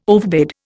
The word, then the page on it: overbid: Indian